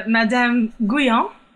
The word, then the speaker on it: Madame Guyon: In the chapter about Black Mass, there is mention of, quote, the last Satanic priestess, Madame Guyon, or something. Can you tell us a little bit about that? – Sure. Madame Guyon, as it would be pronounced in French.